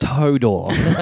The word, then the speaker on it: Hodor: It's pronounced Hodor.